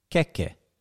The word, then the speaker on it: keke: So the word for cake is keke. – Keke. – Keke. – Keke. – Mm. – But the word for armpit is keke.